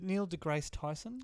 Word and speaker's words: Neil deGrasse Tyson: Is it Neil deGrasse Tyson? – Yes. – Is that how you pronounce it? – Yeah.